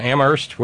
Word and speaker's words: Amherst: That would be thanks to Tom and Sonny San Rafael and Terry in Amherst, where only the H is silent.